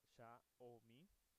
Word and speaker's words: Xiaomi: La palabra Xiaomi, que en realidad se pronuncia Xiaomi,